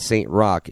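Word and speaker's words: Saint Roch: And Saint Rock is a section of New Orleans, but it's spelled R-O-C-H. But we said our band is going to be Saint Rock, S-T-R-O-C-K.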